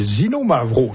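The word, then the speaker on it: Xinomavro: Et parlons aussi d'un autre grand cépage grec qui s'appelle le Zinomavro. J'espère que je prononce bien. – Oui, c'était parfait. – Il vient d'où? – Zinomavro, on trouve plusieurs sur la région du Pelonésie.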